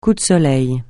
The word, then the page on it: coup de soleil: French